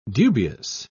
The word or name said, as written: dubious